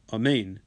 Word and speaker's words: amen: So he's supposed to emphasize the second syllable, amen, rather than amen.